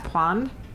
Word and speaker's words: pwned: P-W-N-E-D. How do you pronounce that? Pwned? – Pwned and Pwned Boned.